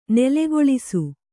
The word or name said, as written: ನೆಲೆಗೊಳಿಸು